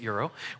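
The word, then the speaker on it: gyro: Next time you're in this town and you go get a euro, yes, that's how you say it, euro.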